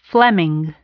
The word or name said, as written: Fleming